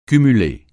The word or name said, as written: cumuler